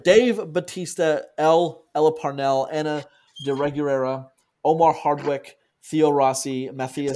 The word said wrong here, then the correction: Omari: It's Omari, thank you. – It's Omari Hardwick. – Oh, sorry. Omari. I said his name wrong.